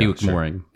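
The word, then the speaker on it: Bewick Moreing: Am I saying that right? – Bewick Mowering and Company. – Yeah, Bewick Mowering.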